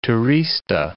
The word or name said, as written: turista